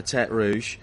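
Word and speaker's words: Tertre Rouge: Then you come down to Turt Rouge, the Red Hill, as it is, Turt Rouge, not Turt Rouge, not Redhead, Hill. Yeah, no, you said it right. A lot of people don't.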